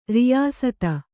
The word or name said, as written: ರಿಯಾಸತ್ತು